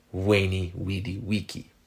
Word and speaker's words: veni vidi vici: Well, it was actually pronounced more like veni vidi vici. Vulgar Latin turned most of those w sounds into v sounds, so the v actually pronounced.